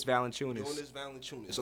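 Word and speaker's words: Valančiūnas: Serge Ibaka over there on that front line next to Viva Valanciunas, whatever you say his name. How do you say his name? – Jonas Valanciunas.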